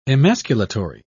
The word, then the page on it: emasculatory: British English